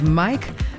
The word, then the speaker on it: Mmyykk: Also an artist that goes by the name of Mike, but it's spelled M-M-Y-Y-K-K.